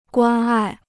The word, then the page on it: 关爱: guān ài